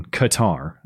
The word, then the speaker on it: Qatar: That soccer journalist who bravely wore a rainbow shirt at the World Cup in Qatar, as I'm supposed to pronounce it. But I like Qatar.